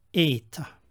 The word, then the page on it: eta: Koine Greek